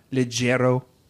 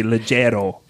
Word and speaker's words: leggero: Are you familiar with the term leggero tenor? I looked up how to say this, and I listened to an Italian man online say leggero.